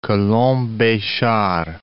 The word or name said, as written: Colomb-Bechar